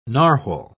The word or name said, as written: narwhal